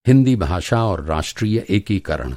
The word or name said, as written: एकीकरण